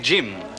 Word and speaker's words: Jim: Il faut prononcer Jim à l'anglaise avec un « de » devant.